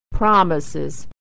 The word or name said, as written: promises